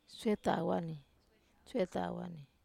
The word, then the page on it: setawa: wamesa